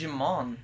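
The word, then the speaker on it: Djimon: I can't pronounce his name, so I call him Dijon. – Wow. – I was like, hey, it's Dijon. – It's just Jimon.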